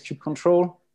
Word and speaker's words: kubectl: Yeah, so I guess back home we say kubectl. But yeah, I guess the right way is kubectl. But I think I hear everyone at work saying kubectl.